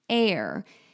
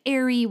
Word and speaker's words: Aire: People were thinking, because it's spelled A-I-R-E. People were saying, how do you pronounce it? And it could be like airy, whatever. And she did say, no, it is air, but it is spelled A-I-R-E. So there's a lot of controversy around this.